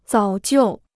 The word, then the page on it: 早就: zǎo jiù